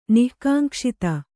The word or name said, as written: ನಿಃಕಾಂಕ್ಷಿತ